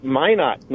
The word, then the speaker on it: Minot: Yeah, it's Minot, North Dakota, is the way you pronounce it.